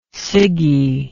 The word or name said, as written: ciggy